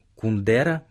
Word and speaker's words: Кундера: Русскому читателю Милан Кундера, в России его фамилию произносят именно так, с ударением на второй слог,